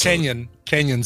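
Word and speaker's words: Cañon: That's Canyon City, yeah. – Oh. It says Canyon with a with an umlaut over it. – A tilde. – Yeah. So it's pronounced canyon.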